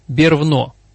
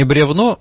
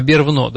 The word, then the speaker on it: бервно: как сейчас до сих пор читается «бервно». Mm. Не бревно, а бервно, да? Вот такой же случай.